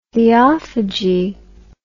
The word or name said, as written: theophagy